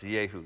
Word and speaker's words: Jehu: There's no J in the Hebrew alphabet. His name is pronounced Jehu.